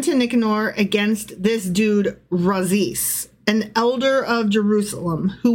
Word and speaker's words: Razis: R-A-Z-I-S. How would you say it? – Razis. – Razis.